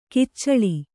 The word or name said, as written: ಕೊಚ್ಚಲು